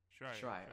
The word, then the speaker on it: Schreier: Schreier? – I'm not going to fuck his name. – Schreier? – Jason Schreier. Schreier, okay. Jason Schreier claims Xbox Scarlet secrecy isn't to surprise Sony.